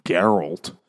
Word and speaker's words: Geralt: It's a very hard G. – Geralt.